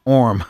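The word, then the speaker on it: Orme: How do you pronounce his last name? Orm. Yeah. O-R-M.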